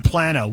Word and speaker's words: Plano: Hey, we got Texans pronouncing it Plano and Plano. Which is it? – It's Plano. It's Plano.